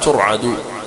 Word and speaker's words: ترعد: وقال الإثيوبي حفظه الله قال هو من الأفعال التي سمعت مبنية للمجهول، من الأفعال التي سمعت مبنية للمجهول، فما وقع في نسخة المجتبى من ضبطه بالبناء للفاعل، يعني ترعد، هذا معنى قوله بضبطه البناء للفاعل بضم القلم، غير صحيح فتنبه. إذن فالصحيح أنها تنطق هكذا ترعد، فجيء بهما ترعد فرائصهما، فجيء بهما ترعد فرائصهما.